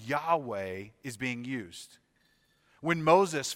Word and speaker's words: Yahweh: And when you take that verb and turn it into a noun, it's pronounced Yahweh.